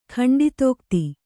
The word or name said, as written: ಖಂಡಿತೋಕ್ತಿ